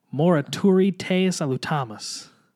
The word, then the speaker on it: Morituri te salutamus: Morituri te salutum. I will say it right and we will edit all this out. Morituri te salutamus. – Thank you, for pronouncing that.